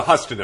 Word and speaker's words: Huston: What was the, is it pronounced Huston or Houston plan? – The Huston affair, yeah.